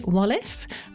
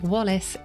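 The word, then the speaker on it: Wallis: So I'm Faye Wallace, and that's Faye without an E on the end, and Wallace is spelt with an IS on the end instead of having an ACE on the end.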